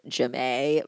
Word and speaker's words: Jamie: and one of them is like a 14-year-old girl named Jemay, but it's spelled like Jamie.